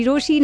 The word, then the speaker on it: Rochie: मेरा नाम है मिर्ची रोशी, नाम की स्पेलिंग है ROCHIE, एंड आई एम वेटिंग जस्ट हियर फ्रॉम यू.